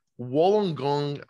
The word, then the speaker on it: Wollongong: And I have you listed as I'm going to definitely mispronounce this, but Wollongong, Australia. So yeah, that's right.